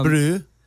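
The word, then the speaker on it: Brü: B-R-U with one of those. What are those things above the U? – Umlaut. – It's called Umlaut, yeah. – Brew. – Pronounced Brew.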